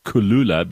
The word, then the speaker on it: Kuloola: Kulula, but it's spelled K-U-L-O-O-L-A, and they're going to be delivering all over South Africa, including to rural areas, which is very, very clever.